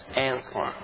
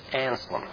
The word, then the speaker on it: Anselm: It's Anselm, S-E-L-M, not S-E-L-E-M. And I spelled it right, but I'm mispronouncing it. So it's Anselm. And there's no E between L and M, either in the literary or in the way you say his name.